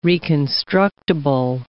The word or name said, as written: reconstructible